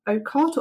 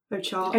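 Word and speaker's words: Orchart: Ocart or Ochart? Ochart.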